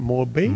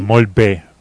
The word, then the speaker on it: molt bé: ¿Cómo se dice muy bien en catalán? Molt bé. – Molt bé. Molt bé. Son dos palabras, molt y bé.